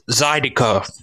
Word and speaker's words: zydeco: It's pronounced Zydeco. – Zydeco. – Zydeco. – Oh. All right. – Oh, okay. Wow. You're just Zydeco. And it's Creole, huh? – It's Creole and Cajun. They both of those genres overlap. – Zydeco. – Zydeco. Oh, yeah. I mean, you look at it, they go, yeah, Zydeco, of course. Yeah. Zydeco. Now that you know how to say it. Yeah. – Co. Yes, that last one I was getting stuck on. Co.